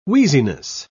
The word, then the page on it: wheeziness: British English